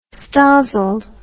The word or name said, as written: stozzled